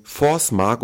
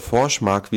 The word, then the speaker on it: Forsmark: im Forsmark oder Forschmark, wie es wohl richtig ausgesprochen wird, nahezu an den Rand einer Katastrophe geführt.